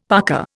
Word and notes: parka: Indian

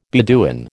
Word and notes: Beduin: American English

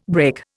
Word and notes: brake: Indian

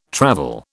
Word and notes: travel: British English

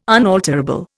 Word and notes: unalterable: Indian

